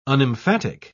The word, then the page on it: unemphatic: British English